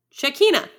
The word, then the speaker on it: Shekinah: That might, I think it ends in an ah. Shekinah. Look, I was, I'm great. – John Shekinah.